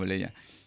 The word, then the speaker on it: Lenia: Lenia, como se pronuncia como Lenia. Lenia Batres, pero le decimos Lenia, está bien.